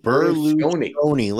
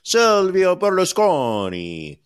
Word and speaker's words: Berlusconi: Berlusconi. Listen, as somebody who's a quarter Italian, I will pronounce it properly. Silvio Berlusconi, the king of the Bunga Bunga parties.